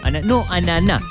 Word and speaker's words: ananá: Ananá, con acento en la A.